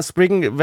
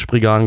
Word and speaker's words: Sprigan: Ja, Springen wäre eine englische Aussprache, aber äh, in der japanischen wie als auch in der deutschen Synchro wird Sprigan gesagt.